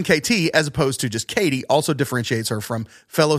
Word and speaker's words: KT: Here's a little bit more on Katie. This is from Wikipedia. Tunstall's first name is Kate, but she chooses to use her initials KT, pronounced Katie, instead, saying, quote, "Katie just makes me think of a buxom lass baking bread for her man working in the fields.